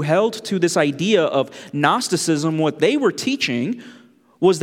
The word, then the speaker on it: Gnosticism: Gnosticism. There's a silent G in front of that.